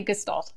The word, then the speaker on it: Gestalt: So that would be an older person who is still using the, and you're pronouncing it Gestalt, is that right? – I've heard Gestalt, and I've heard Gestalt. So Gestalt, either, oh, that's interesting. – slight shift, but I say Gestalt.